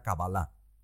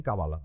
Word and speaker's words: Kabbalah: lo, lo, importante, hombre, yo soy partidario de pronunciarlo como se pronuncia en el hebreo original, que sería Kabbalah. Pero está bien Kabbalah también.